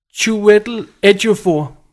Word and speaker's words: Chiwetel Ejiofor: Jeg fandt ud, hvordan man skal udtale det. Chiwetel Ejiofor. Chiwetel Ejiofor. – Okay. Det er ham fra Serenity. – Altså Chewy, som om man siger Chewbacca.